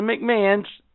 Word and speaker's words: McMahon: Keep in mind, the McMahons, these are people, right? – You gotta say it like McMahon.